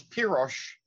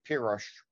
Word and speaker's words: Pirosh: Really, because Pyrosh, is the Hungarian word for red, Pirosh. That's how it's pronounced. It's a Hungarian name or Hungarian word, Pirosh.